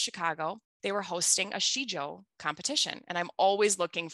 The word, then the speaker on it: Sijo: I learned about Shijo, and it's written S-I-J-O, pronounced Shijo, a little over a decade ago.